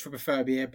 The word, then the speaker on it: trypophobia: I think it's pronounced trypophobia, and isn't it the fear of like those little holes and things? – To be fair, it is pronounced trypophobia, but I specifically said it wrong just to try and...